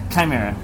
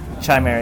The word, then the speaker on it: Chimera: Yeah, Chimera. Chimera Brewing. Chimera. It's the H is silent.